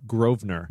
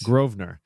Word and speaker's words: Grosvenor: It's Ed Grosvenor. – The S is silent. – Of course it is. – Grosvenor.